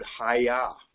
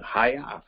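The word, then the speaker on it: haya (היה): "haya." Sounds like a karate word, doesn't it? Haya.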